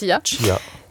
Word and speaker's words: Chia: Chia, ouais, c'est la bonne prononciation. – Très bien prononcé. Chia.